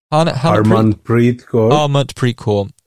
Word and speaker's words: Harmanpreet Kaur: Then you had Jemima Rodriguez and am I pronouncing this right? Harpreet Kaur? – Harmanpreet Kaur. Harman.